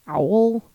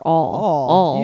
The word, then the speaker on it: awl: I've had this debate with on the pronunciation of the tool, an owl, an AWL, because I pronounce it owl, or all.